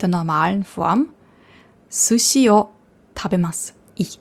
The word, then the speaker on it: tabemasu: Tabemas. Tabemas. Das heißt zwar tabemasu, aber das U wird ja nicht ausgesprochen, das verschluckt.